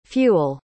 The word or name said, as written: fewel